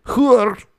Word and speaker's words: HEUR: and hur, that's how it's said, that's how you say it, H-E-U-R, colon, trojan.win32.generic.